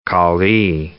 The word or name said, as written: callee